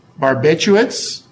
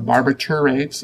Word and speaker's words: barbiturates: Barbiturates, or barbiturates, if you prefer that pronunciation.